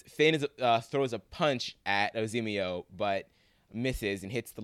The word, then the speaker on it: Azimio: Say his name for me one time, Matt. – Azimio. – Oh my God, great.